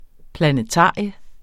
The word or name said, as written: planetarium